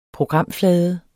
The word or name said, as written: programflade